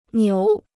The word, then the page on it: 牛: niú